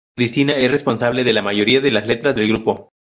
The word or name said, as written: Cristina